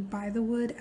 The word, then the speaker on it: Bythewood: The director is named Gina Prince Bythewood. Her last name is hyphenated, and the Bythewood actually is spelled the way that it sounds, B-Y-T-H-E-W-O-O-D.